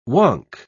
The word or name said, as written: wonk